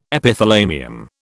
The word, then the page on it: Epithalamium: British English